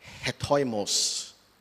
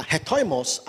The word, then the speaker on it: hetoimos: The first one is the word hetoimos.